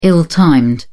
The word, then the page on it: ill timed: British English